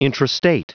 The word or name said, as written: intrastate